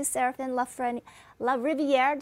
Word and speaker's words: Serafin LaRiviere: But I want to say hello to Serafin LaRiviere. Did I pronounce it right, Serafin? – Yep, LaRiviere. That's just perfect.